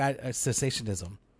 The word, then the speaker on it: cessationism: because I didn't really have a clear understanding of cessationalism, right? Like cessationism. Is that, did I say it right? – You got it. – All right. Joe was like shaking his head at me. – Well, the first time you got it wrong.